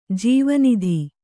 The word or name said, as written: ಜೀವನದಿ